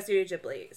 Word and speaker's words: Studio Ghibli: Yeah, Studio Ghibli.